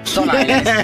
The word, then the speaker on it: Stone Island: Stone Island.